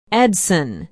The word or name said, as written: edson